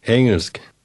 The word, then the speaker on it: Engelsk: Engelsk.